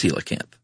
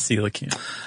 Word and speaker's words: coelacanth: And B, it's spelled, coelacanth, which is, not how you would think it might be spelled. – No. – Or pronounced, rather. – Right. Either one. – But it's coelacanth. – It is coelacanth.